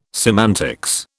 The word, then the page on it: semantics: British English